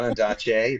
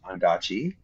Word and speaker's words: Ondaatje: I just found out that I've been saying his name wrong my entire life. – Oh, no. – It's not Ondaatje, it's Ondaatje. Right from the man himself.